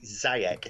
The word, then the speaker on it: Ziyech: because they've got is it Ziyech,